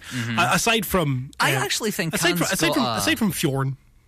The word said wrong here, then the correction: Fjord: Fjord. Yeah. – You're going to have to say it like Swedish Chef.